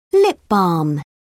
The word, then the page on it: lip balm: British English